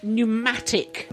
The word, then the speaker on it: pneumatic: Pneumatic. – That's a silent P.